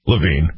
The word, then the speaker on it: Levine: Levine, Adam Levine, yeah.